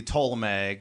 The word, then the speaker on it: Ptolemy: Yeah, it's more English would be Ptolemy. Greek would be Ptolemy. They actually pronounce the P, Ptolemy. – Put the p in there.